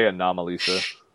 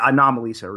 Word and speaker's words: Anomalisa: just Don't be surprised if Anomalisa, if that's even how you pronounce it, I don't know. – I would say Anomalisa. – Yeah, Anomalisa, that's right.